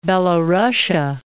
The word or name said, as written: Belorussia